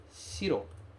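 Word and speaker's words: shiro (白): Nesse caso, este kanji vai ter a pronúncia shiro.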